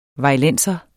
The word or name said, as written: vejlenser